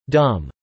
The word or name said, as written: dumb